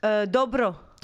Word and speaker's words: Dobro: Dobro.